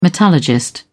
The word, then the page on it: metallurgist: British English